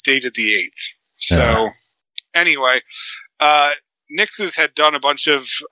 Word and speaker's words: Nixxes: So Sony has finally bought, I believe they're pronounced Nixes, N-I-X-X-E-S.